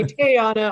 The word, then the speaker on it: Teauhna: Teauhna. – And that is pronounced Teauhna. – Yes, please. Thank you. And if you say Teauhna, I'll still accept that.